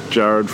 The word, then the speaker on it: Gerard: Yeah, but pronounced differently, similar to my name. okay. I don't know if mum and dad just moved me there as a cool trick. So you can be like, oh, it's Jared from Scone, or is it Jared from Scone?